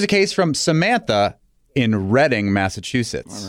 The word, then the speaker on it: Reading: Well, let's go back for a second, because Woburn is spelled W-O-B-U-R-N. – Yes. And Reading is spelled like the word reading. – Right. But it's Reading, like Reading, England.